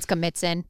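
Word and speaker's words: Schemitzun: But anyway, it was, it's called Skamitzin. – Skamitzin? Can you spell that? – S-C-H-E. – S-C-H-E. – M-I-T-Z-E-N, Skamitzin.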